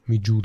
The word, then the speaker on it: Mju: Mm, Olympus Miju, das sind Miju, also, das spricht man die eigentlich so aus, das macht mein Kopf immer. M-J-U. Me Ju, glaube ich.